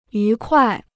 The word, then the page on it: 愉快: yú kuài